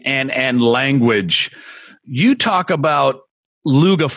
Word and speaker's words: Lugha: So Luga is a Swahili word that means languages. And, you know, it's spelled L-U-G-H-A, Luga. You know, that's Swahili. Mm-hmm. You know, but coming from Uganda, we use the L-U-G-A because we are a Bantu, so we don't have the H in our expression.